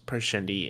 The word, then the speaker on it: Parshendi: So my assumption is wherever this Everstorm is really, like, there's more than just it turns Parshendi into storm form Parchment. I said that wrong.